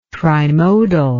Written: trimodal

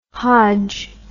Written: hodge

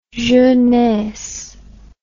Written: jeunesse

